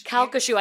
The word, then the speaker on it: Calcasieu: Calcasieu? – Calcasieu. I had to look up the pronunciation.